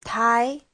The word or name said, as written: tai2